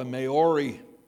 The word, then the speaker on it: Maori: But down in New Zealand, how do I say this, pronounce this name? Morar, M A O R I. Somebody pronounce that for me.